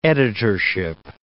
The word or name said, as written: editorship